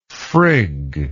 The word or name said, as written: frigg